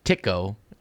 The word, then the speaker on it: Tycho: Uh, we have not previously talked about uh, the 400 year old mystery, mystery in quotes, surrounding Tycho Brahe, or Tycho, as his name I think properly is pronounced.